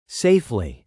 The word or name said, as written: safely